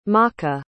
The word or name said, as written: maka